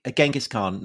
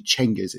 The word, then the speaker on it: Genghis: So Ali, Genghis Khan, not the correct pronunciation, right? – It isn't actually. Well, as far as we can tell, because actually the more accurate pronunciation or rendition seems to be, and I dare say among Persian historians of the Mongol period, and it's actually Genghis. It's a CH rather than a G.